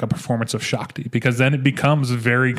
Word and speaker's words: Shakti: So yeah, this is dedicated to Shakti. I believe it's how it's pronounced. S-H-A-K-T-I. So I think it's Shakti.